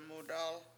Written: mudAl

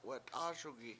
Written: wat7ASugi

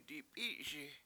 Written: dip$I7Si7

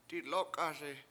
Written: dilOk7ASi

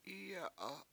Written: Iye7a7

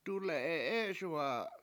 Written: dule7e7ESuwa7a

